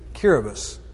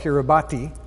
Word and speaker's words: Kiribati: And it's another one of these linguistic things, but Kiribati is spelled Kiribati. And just like I before E, except after C, the T-I in a sentence is pronounced as an S some of the time.